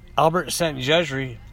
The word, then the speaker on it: Szent-Györgyi: Albert Szent-Györgyi, a brilliant Hungarian scientist who won two Nobel Prizes in 1937 and 1955 for his research.